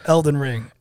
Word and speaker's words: Elden Ring: Get back to playing your fucking Elden Ring. Shit. – Yeah, you said it right. Elden Ring.